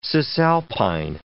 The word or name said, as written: cisalpine